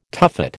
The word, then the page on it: tuffet: American English